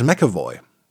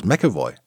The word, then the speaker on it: McAvoy: Ach, den, äh, James, James, James McAvoy. So spricht man es britisch aus. McAvoy. Nicht etwa McAvoy. Die Deutschen wollen das immer sehr korrekt aussprechen, sagen das Mac immer, aber macht man eigentlich im Englischen nicht.